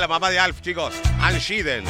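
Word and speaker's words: Anne Schedeen: Ann Sheeden. Así se pronunciaba, mirá, no sabía.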